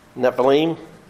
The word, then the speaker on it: Nephilim: Nephilim? I'm not sure how you say it. Nephilim? – Nephilim.